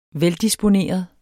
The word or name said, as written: veldisponeret